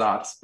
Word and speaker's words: Saaz: The more typical or traditional hopping would be the Saaz hop from the Czech Republic, S-A-A-Z. It's actually pronounced Zatz, but nobody knows that but us.